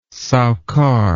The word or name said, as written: sowcar